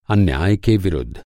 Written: विरुद्ध

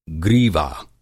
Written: ग्रीवा

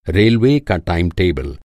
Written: टाइम-टेबुल

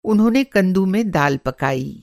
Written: कंदु

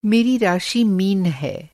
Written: मीन

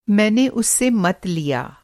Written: मंत

